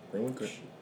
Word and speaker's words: Greenwich: I think it's called. How you pronounce it? – Greenwich.